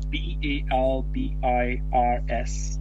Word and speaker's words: Balbir's: Um, How do you say it? Bal- Balbir's. – Yeah. Uh, B A L B I R S. Balbir's. Oh, Balbir's.